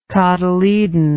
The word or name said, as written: cotyledonous